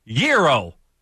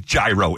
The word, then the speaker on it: gyro: It's Greek, so naturally the spelling isn't familiar to you guys, but indeed the proper pronunciation is gyro.